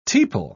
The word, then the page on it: tepal: British English